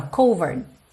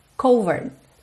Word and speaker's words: covered: Porque você leria ali sem saber, se você não fosse seguidor, seguidora aqui do canal, você ia pensar covered, mas esse E final não é pronunciado. Se ele não é pronunciado, essa minha última sílaba fica um RD, ou seja, quase não aparece, ó, covered, covered. Lembra que o D é a consoante oclusiva que pode aparecer mais, menos ou nada?